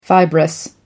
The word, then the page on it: fibrous: American English